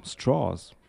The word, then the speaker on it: Strauss: Wie Strohhalm, Strauss.